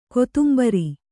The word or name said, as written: ಕೊತ್ತುಂಬುರಿ